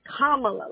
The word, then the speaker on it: Kamala: It's Kamala, like Llama, comma. – Kamala Harris. – Like a comma in a sentence. So comma and la, comma, la. So Kamala Harris.